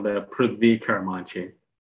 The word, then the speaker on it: Prudhvi Karumanchi: My name is spelled Prithvi Karamanchi.